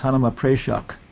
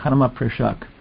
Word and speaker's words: Hanuma Prashak: I was saying Hanuma Prashak, but Vrithidayananda Maharaj, everybody says, no, it's not a long E, it's Hanuma Prashak. Prashak, Hanuma Prashak.